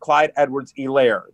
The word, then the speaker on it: Edwards-Helaire: But I've been told, actually, it's pronounced Clyde Edwards-Helaire, that the H is sort of silent.